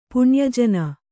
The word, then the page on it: ಪುಣ್ಯಜನ: puṇya jana